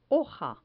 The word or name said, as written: hoja